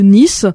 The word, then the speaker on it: Neath: Donc, Buffalo Summer, c'est un groupe originaire de la ville galloise de Nice. Ça s'écrit N-E-A-T-H.